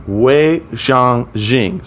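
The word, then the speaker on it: Wei Zhongxian: Yeah, Wei Shang Jing. So it's W-E-I space Z-H-O-N-G X-I-A-N pronunciation.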